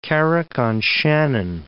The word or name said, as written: Carrick on Shannon